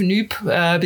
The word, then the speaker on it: NUPES: Because the French say NUPES. – We're right into us with your preferred pronunciation. – Yes. So I go with noob because there's a certain panache behind that, and it also for me reflects a little bit the Jean-Luc Mélenchon kind of no saying kind of attitude.